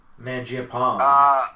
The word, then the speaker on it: Mangiapane: Was that an on purpose pronunciation or that's how you think it's pronounced? – Mangiapane. – It's Mangiapane.